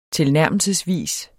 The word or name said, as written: tilnærmelsesvis